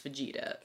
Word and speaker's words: Fujita: It also looks a lot like fajita, but ooh, then I was looking up how you pronounce it, and the internet insisted that it's Fujita.